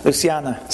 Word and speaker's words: Luciana: So he says, Luciana and I, how do you say that in Brazilian? In Portuguese? Luciana? – Luciana. – See, it sounds so much better.